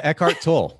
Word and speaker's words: Eckhart Tolle: Eckhart Tolle.